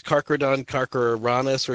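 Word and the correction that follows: Carcharodon carcharias: or something like that. Was that the megalodon that you were referring to? – Yeah, if I remember correctly, he got real big into sharks after this. It's Carcharodon, Carcharias. – Right. That's probably better pronounced.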